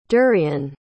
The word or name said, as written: derian